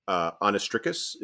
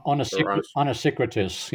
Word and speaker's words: Onesicritus: where you have this encounter between a Cynic Onesicritus is how you pronounce his name. – Onesicritus, yeah.